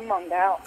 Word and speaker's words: Mongal: Is your name in the movie pronounced Mongal? – Yes. – I knew it. – Mongol the Elder. It's my dad. I'm Mongal.